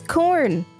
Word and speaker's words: Quorn: Today, however, grain crops still do not grow further north than corn. That's spelled Q-U-O-R-N. – It's corn.